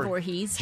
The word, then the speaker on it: Voorhees: Am I pronouncing that right? – Jason Voorhees.